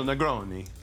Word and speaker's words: Negroni: I really hope I'm pronouncing that correctly. – Sounds right. – Yeah. – So, Camille Negroni. – Negroni, right. A little, yeah.